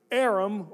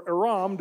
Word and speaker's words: Aram: In the Old Testament, this country, Aram, or Aram, depending on how you want to pronounce it, it was always attacking and going to war with Israel.